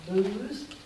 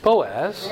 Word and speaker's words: Boaz: Booze? – Boaz.